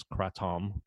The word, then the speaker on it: kratom: I've been told the pronunciation of it is Kratom because it's a Thai word.